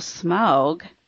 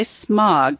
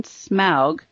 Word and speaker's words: Smaug: Our conundrum is will Smaug, and I hope I said that right, because I know once in a while I say smog, but I mean smaug.